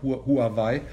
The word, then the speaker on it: Huawei: So before we talk on Huawei, for the German listeners who are not too familiar with Chinese, we're talking about Huawei. Because this is the pinyin, how it is spelled, and in German marketing it is called Huawei. In Chinese, though, you call it Huawei, and I think some people might not have realized yet that we're talking about this company.